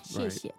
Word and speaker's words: 谢谢: The standard is 谢谢。 – 谢谢。 – 谢谢。